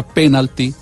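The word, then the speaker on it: pénalti: Entonces el diccionario de americanismos, que es el nuevo diccionario normativo que tiene la academia, sí recoge la palabra Penalty, esdrújula, con tilde en la E, que es como la pronuncian la mayoría de locutores y narradores en Colombia.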